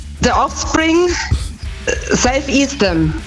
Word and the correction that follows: Self Esteem: Ja, Self Esteem nehmen wir auch.